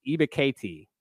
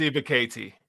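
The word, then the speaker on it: Ebiketie: I really like Arnold, and I believe I thought I was saying it right the whole offseason, but recently I was corrected. It's Ibukati, I believe. – Yeah, it's Ibukati.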